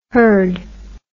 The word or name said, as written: hurd